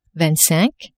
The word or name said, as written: vingt-cinq